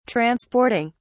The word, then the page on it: transporting: British English